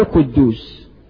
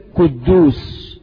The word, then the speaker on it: قدوس: أولا، كثير من الناس بتنطق هذا الاسم خطأ، ولك الحج عبد القدوس، مش اسمه قدوس يا حج، اسمه قدوس، ولازم تنطقها هكذا، لأن أسماء ربنا توقيفية زي آيات القرآن.